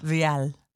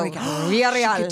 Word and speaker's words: Villarreal: Villarreal.